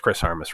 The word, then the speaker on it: Armas: as it is Chris Armas, right, Ryan? I didn't butcher his name. Is that correct? Yeah, Chris. Okay, yeah, Chris Armas.